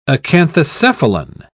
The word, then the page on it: acanthocephalan: British English